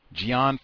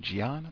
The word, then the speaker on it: Ghian: Chicago Police Board President Gian Foreman, hope that's how you say it, G-H-I-A-N, Gian, I think that's it,